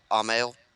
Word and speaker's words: Amell: The main actor, Stephen, what is it, Amell? – Yeah.